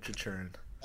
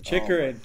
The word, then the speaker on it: Chychrun: That's the best defenseman in the draft, Jacob Chychrun. – Chychrun.